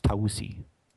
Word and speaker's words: Taus: And because Azazel passed that test, God raised him above the rest of the angels and gave him the title of Melech Taus, or sometimes pronounced Tausi.